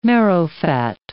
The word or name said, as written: marrowfat